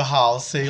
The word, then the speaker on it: Halsey: Gente, a cantora alternativa Halsey lançou o single Graveyard.